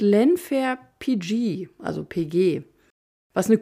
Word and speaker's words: Llanfair: Er wird nicht englisch, sondern walisisch ausgesprochen und ich muss sagen, beim Versuch, das auszubesprechen, da habe ich mich so beömmelt. Googelt das mal. Lenfair wird geschrieben L-L-A-N-F-A-I-R.